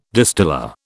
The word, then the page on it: distiller: British English